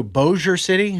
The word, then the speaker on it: Bossier City: Then we can go to Bossier City.